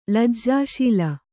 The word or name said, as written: ಲಜ್ಜಾಶೀಲ